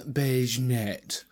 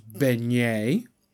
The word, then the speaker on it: beignet: Beige Net. Yeah, it's not pronounced beige net. I didn't think it was, to be fair. It is French, and it's pronounced beignet. So it's a banana beignet.